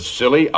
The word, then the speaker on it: Scilly: The Scilly Isles? That sounds appropriate, Doctor. – The name of these particular islands is spelled S-C-I-L-L-Y.